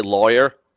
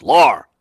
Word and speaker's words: Lawr: I always would say lawyer And I don't know what you're saying. It sounds like Lawr Michaels from Saturday Night Live. But it took all of us like 4 or 5 years to say it right. Every season, I love you, Scott, but it's Lawr. Lawr.